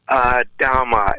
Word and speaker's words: Adhama: Yes. Adhama. It's like Adam with an A at the end.